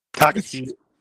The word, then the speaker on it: Takacs: I'm probably going to mispronounce this name, but George Takacs. – Takacs. – Yeah, T-A-K-A-C-S. He had 7 catches for 84 against Rutgers, so he has at least gotten off to a hot start this year.